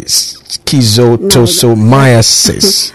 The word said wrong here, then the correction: schistosomiasis: No, that is schistosomiasis.